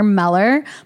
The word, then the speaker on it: Mueller: If you guys are looking for some great Botox and lip filler in Beverly Hills, his name is Dr. Meller, but it's spelled like Mueller, like M-U-E-L-L-E-R, Gregory Meller.